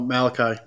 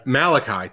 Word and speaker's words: Malachi: Oh, Malachi. – Malachi.